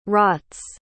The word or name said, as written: rots